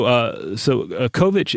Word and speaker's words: Kovač: This is K-O-V-I-C with the last part having a C-H pronunciation like in chocolate. So Kovač